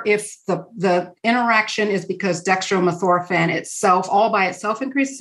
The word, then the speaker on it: dextromethorphan: Did you say it's dextromethorphan that's a cough suppressant? – Yes. – Did I say that right? – Yes.